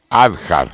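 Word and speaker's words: Hadjar: Gasly larga noveno por sanción a Adjar. Adjar, en realidad. Se escribe H-A. de JAR.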